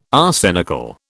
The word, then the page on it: arsenical: British English